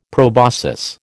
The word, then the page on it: proboscis: American English